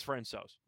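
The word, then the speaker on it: François: It's Francois. – Where did you Google it? I mean, did you saw some video and stuff where they called him Francois? – Yeah, I found some videos, some pronunciation on some hockey websites.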